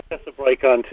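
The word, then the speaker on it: Hyphessobrycon: You also are working with Hyphesobacron, and how would you pronounce that? – Which one, the Wadi or just the genus name? Did I butcher it, or Hyphesobrychon? – Hyphesobrychon too.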